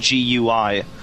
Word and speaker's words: GUI: Yeah, the name actually came from, in technology, there's something called GUI, which is pronounced GUI.